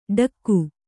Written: ಡಕ್ಕು

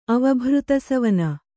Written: ಅವಭೃತಸವನ

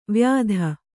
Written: ವಾಯಿದೆ

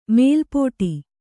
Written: ಮೆಲ್ಪಾಡು

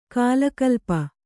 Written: ಕಲಾಕಲಾಪ

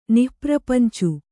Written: ನಿಃಪ್ರಪಂಚಿ

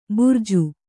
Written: ಭರ್ಜಿ

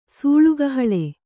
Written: ಸೂಳುಗಹಳೆ

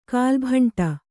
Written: ಕಲ್ಲುಬಂಡೆ

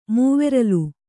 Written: ಮೂವೆರಲು

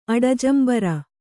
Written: ಅಡಜಂಬರ

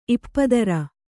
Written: ಇಪ್ಪದರ

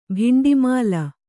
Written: ಭಿಂಡಿಮಾಲ

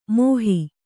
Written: ಮೋಹಿ

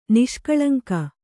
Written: ನಿಷ್ಕಳಂಕ

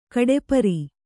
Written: ಗಡಪಾರೆ

